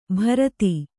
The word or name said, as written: ಭರತಿ